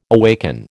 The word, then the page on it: awaken: American English